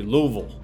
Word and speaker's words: Louisville: Now, if you're from Cincinnati and you know there's some people from Louisville around, you can't say Louisville, you can't say Louisville, it has to be Louisville. Louisville is the correct way to pronounce Louisville.